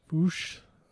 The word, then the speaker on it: Fuchs: That's Fuchs. Oh. I almost like there's a certain way, like I know it's not pronounced, but if you say it a certain way, it's the bad word, so I'm not going to say it.